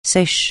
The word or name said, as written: sèche